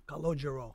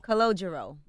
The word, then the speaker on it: Calogero: Calogero. – Calogero.